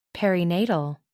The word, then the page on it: perinatal: American English